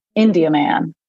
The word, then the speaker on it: Indiaman: And the proper way to say it is literally like you're saying India and then you're saying man, but it's together. – India man.